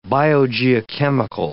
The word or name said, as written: biogeochemical